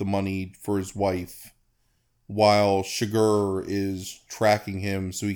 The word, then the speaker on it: Chigurh: At that point, Anton Chigurh, who's the Javier Bardem portrays Anton Chigurh, to say it correctly, who's a presumed mob assassin / serial killer, gets on his trail.